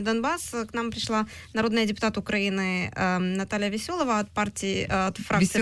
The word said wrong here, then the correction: Веселова: Веселова. Простите, у меня в русском языке ударения часто смещаются.